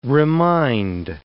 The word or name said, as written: remind